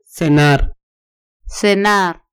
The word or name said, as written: cenar